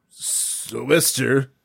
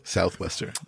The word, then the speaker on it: sou'wester: sou'wester, probably pronounced Southwester. Sou'wester. – Sou'wester.